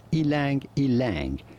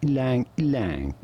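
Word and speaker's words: ylang-ylang: Many say it as ilang ilang, but in the countries it originates from, it is pronounced something more like lang lang, almost like the wild.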